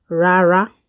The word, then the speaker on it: rara: No is rara, ra, ra, rara. That is how you say no.